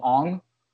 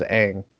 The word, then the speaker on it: Aang: Even though technically speaking, and I'm using quotations, they're kind of correct on the pronunciation, but we all know him as Aang.